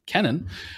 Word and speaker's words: Kennan: So I am listening right now to John Lewis Gaddis, the Yale historian's biography of George, is it Kennan or Kennan? I realize I actually don't know. I'm embarrassed to say I don't know how to pronounce. It's Kennan. Okay, Kennan.